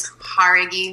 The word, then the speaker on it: Jauregui: So that pronunciation on that last name is Jauregui, Mike. You're going to get it one day. I really believe it. It's been Jaguar, Jauregui, and now it's today.